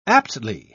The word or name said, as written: aptly